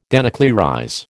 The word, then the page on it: denuclearize: American English